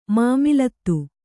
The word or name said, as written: ಮಾಮಲತಿ